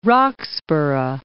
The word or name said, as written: Roxburgh